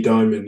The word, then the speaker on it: Dimon: We've got everyone's favorite CEO, JP Morgan, is it Diamond? Oh, it's pronounced? – Yeah, Jamie Dimon, yeah.